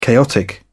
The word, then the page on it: chaotic: British English